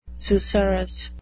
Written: susurrus

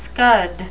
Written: scud

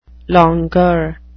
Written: longueur

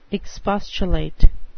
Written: expostulate

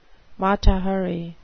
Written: mata hari

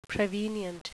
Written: prevenient

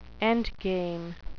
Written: endgame

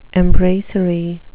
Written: embracery

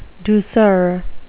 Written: douceur

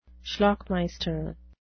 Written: schlockmeister

